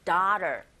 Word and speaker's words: daughter: 역시, as I told you, daughter 아니라 daughter 이렇게 발음하네요. 역시 T 사운드 정확하게 들렸고요.